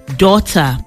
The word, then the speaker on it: daughter: D-A-U-G-H-T-E-R, daughter. In the word daughter, it seems we couldn't hear the sounds made by GH at all, right? Now do this one. How will you pronounce this? C-O-U-G-H.